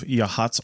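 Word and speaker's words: Yachats: We're in a nice little spot on the Oregon coast, just south of Yahats, Oregon. Spelled Yachits, if you're trying to find it on a map. – Yes. It does not look like it should be pronounced that way, for right.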